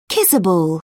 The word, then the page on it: kissable: British English